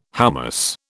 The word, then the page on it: hoummos: British English